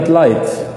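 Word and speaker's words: light: يعني مثلا كلمة لايت، لايت فيها أكثر من حرف لا يكتب لا ينطق، مثل الجي والإتش ما تنطق، مثل كلمات كثيرة، كلمات كثيرة.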